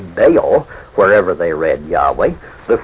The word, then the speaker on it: Baal: Usually this was the Hebrew word Baal, in English spelt B-A-A-L, and usually pronounced Baal.